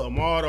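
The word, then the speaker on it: Ahmaud: Ahmad Arbery.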